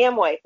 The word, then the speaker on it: Amway: Amway, thank you.